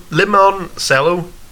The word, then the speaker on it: limoncello: It's not lemon because it's spelled L-I. Lemon. Limoncello. Cello.